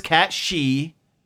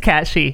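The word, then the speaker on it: Cait Sith: And I don't care that his name is Cat She. – Really? That's how they say it's pronounced? – That is how... Well, it is Cat She. – In Irish folklore, it's Cat She.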